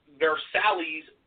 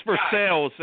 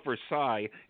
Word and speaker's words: Versailles: Versailles, PA. I'm sure I butchered that, too. My point is... – Well, it's Versailles. That's the way they pronounce it. It's, in France, I'd call it Versailles.